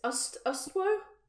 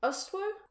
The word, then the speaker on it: Ustwo: Well, I thought it was just like us too. – Oh my god, what? Okay, I'm giving you full permission to leave that in. – I would be doing that with or without your permission, mate. – No! Yeah, okay, so... – I mean, I might be wrong. – What? – I don't know. It's spelled U-S-T-W-O. – Ustwo would make sense.